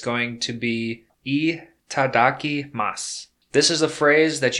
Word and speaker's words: itadakimasu: Once again, that's itadaki masu. It's spelled itadakimasu. Itadaki masu.